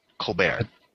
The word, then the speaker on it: Colbert: Collectively, from all of us, Mr. How's it pronounced? Is it Colbert? – Colbert. – Colbert? – Colbert. – Mr. Colbert. – Colbert. – Colbert. I got it now.